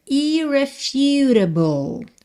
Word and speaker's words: irrefutable: Oh, my irrefutable father, irrefutable, ok? Atenção que esse U tem som de U, então, irrefutable father.